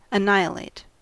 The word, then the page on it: annihilate: American English